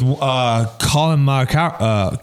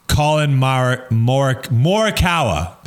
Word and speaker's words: Morikawa: Yeah, I think Dude Perfect just did this with Colin Morikawa. Gosh, I butchered his last name. Colin Morikawa. There it is.